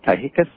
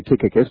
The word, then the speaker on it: Tychicus: In, what are they, Ephesians, I've got it here, 6:21, Paul sends, I'm probably going to butcher his name, Tychicus, I guess it is. – I would say Tychicus, but go ahead. – Okay, Tychicus, there we go.